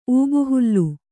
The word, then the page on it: ಊಬುಹುಲ್ಲು: ūbuhullu